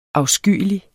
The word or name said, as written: afskyelig